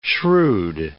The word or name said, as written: shrewd